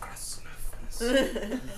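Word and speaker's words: Krasnoturinsk: No, it's actually pronounced Krasnuthmisk, Sverdlovsk Oblast. Russia.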